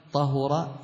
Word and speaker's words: طهر: طهر يطهر بضم الهاء فيهما، يعني في كلمة طهر ويطهر نضم الهاء فننطقها هكذا.